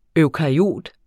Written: eukaryot

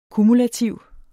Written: kumulativ

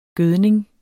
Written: gødning

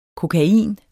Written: kokain

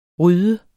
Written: rydde

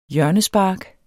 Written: hjørnespark

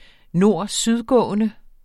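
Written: nord-syd-gående

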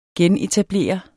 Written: genetablere

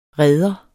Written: reder

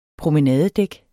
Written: promenadedæk

